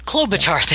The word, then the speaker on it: Klobuchar: Klobuchar? – Klobuchar? – Klobuchar, yeah. That's it.